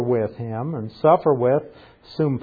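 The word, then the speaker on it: Soon (σύν): Soon is how it's pronounced.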